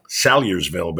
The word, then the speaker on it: Salyersville: And I've learned to pronounce it now, Salyersville. But he said, man, you called that Salyersville. Every damn thing but Salyersville on the air that day.